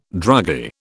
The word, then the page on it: druggy: British English